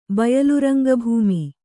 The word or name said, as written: ಬಯಲುರಂಗಭೂಮಿ